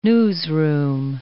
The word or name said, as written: newsroom